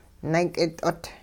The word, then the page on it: naak'it'ot: hupa